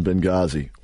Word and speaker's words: Benghazi: Yeah, really the H is silent in Benghazi.